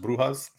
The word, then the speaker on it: Brujas: They're also known as La Brujas because I found out last season in Spanish it's pronounced Brujas.